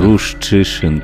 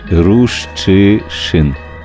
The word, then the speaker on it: Рущишин: Оце в Києві можна робити змагання на це чи можу вимовити Рущишин, Юрчишин. Ну, Рущишин, я я, це типу, все галицькі, типу, галицькі ру, прізвища. Рущишин, правильно? Да, бляха, капець. Ярослав Рущи, Рущишин. Правильно? Тепер? Бінго. Бінго.